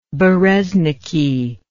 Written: berezniki

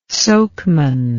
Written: sokeman

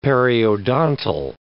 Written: periodontal

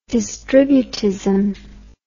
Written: distributism

